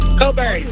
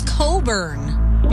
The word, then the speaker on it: Cockburn: Coburn. – There you go. Knew it's Coburn. – But spelt exactly the way you said it the first time.